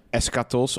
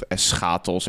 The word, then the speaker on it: eschatos: En eschatos of eschatos, als je het heel Nederlands uitspreken.